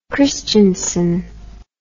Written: Kristiansen

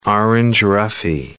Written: orange roughy

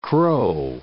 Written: crow

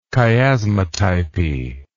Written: chiasmatypy

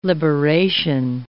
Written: liberation

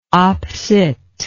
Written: op. cit.

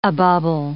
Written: a bubble

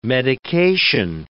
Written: medication

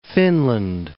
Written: Finland

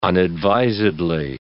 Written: unadvisedly

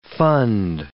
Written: fund

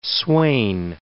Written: swain

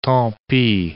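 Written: tantalate

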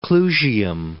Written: Clusium

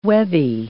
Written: webby

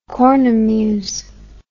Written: cornemuse

